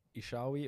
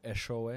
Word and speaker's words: Eshowe: So I come from a small farming town called Ishawe, or Eshowe, if you want to say it properly.